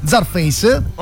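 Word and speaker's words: Czarface: L'album si intitola diciamo, no, Scarface, ma CZ si pronuncia Zarface, perché la CZ non si pronuncia Kza, capito? – Sì, okay.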